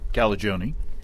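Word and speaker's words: Calagione: He's worked with Dogfish Head brewer Sam Caligione.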